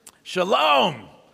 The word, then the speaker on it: Shalom: Shalom, which is the customary Jewish greeting or for farewell.